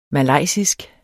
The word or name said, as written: malaysisk